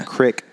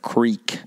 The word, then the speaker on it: creek: But not a crick crossing. – Not a crick. – What's a crick? – A crick is a mispronounced version of creek.